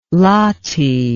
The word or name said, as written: lathi